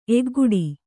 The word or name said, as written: ಎಕ್ಕಾಗಾಡಿ